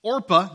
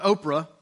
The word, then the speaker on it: Orpah: But the other one, Orpah, not Oprah, Orpah, I think is how you say it, she decides, okay, I'll listen to you.